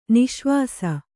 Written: ನಿಃಶ್ವಾಸ

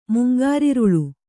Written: ಮುಂಗಾರಿರುಳು

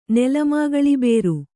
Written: ನೆಲಮಾಗಳಿಬೇರು

